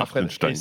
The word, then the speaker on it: Hartenstein: Tu le prononces très très bien, Hartenstein.